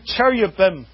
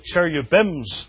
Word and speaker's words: cherubim: Right there on top of the ark, between these creatures called the two cherubim. And you'll notice that I don't pronounce it cherubims, though that's what it is in the English here. Because I wouldn't say one man and two men's.